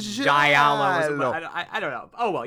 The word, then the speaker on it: giallo: Giallo. – I took Italian diction. You have to pronounce each vowel, so it's giallo, but when you say it fast enough, it's just giallo.